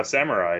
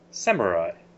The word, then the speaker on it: samurai: She insisted that it was samurai, and I kept telling her, like, Mom, it's a Japanese word, and that syllable does not exist in Japanese.